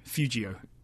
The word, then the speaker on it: Feudjio: It's spelled like feud, like family feud. uh, J I O, Feudjio.